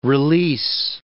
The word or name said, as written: release